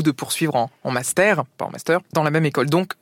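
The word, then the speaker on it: mastère: Alors, j'ai vu plusieurs manières, mais la plus répandue, c'est donc M-A-S-T, accent grave, R-E. Donc, ça se prononce master. Mais justement, à l'oral, master, on fait très peu la différence.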